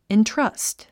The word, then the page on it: entrust: intrʌ́st; イントラァスト